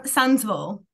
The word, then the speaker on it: Sundsvall: So I think that the world you have created in Sundsvall, have I pronounced it right? How am I? – Sunsville. – Sundsvall.